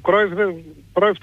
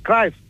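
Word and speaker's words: Cruyff: Λοιπόν, όπως και ο Κρόιφ, το λέγαμε εμείς, Κράιφ.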